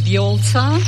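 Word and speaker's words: Vjollca: My name is Violza.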